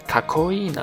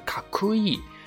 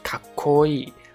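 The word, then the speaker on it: かっこいい: 比如说卡哇伊是可爱，卡扣伊呢，可能和一些没有接触过日语的人来说，哎，和自己的印象当中好像读音不同啊。可能大家读音当中呢是卡酷伊啊，然后是帅的意思，酷的意思啊，但是呢，它其实是卡扣伊。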